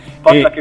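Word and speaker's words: potlatch: Dunque, Potlak, è giusto? Come si pronuncia? – Potlak, ecco, Potlak. La pronuncia non abbiamo modo di verificarla perché è una pratica degli indiani della costa nord-occidentale degli Stati Uniti. – Ah, ho capito, vabbè, comunque noi diciamo Potlak perché ci viene meglio, no? – Sì.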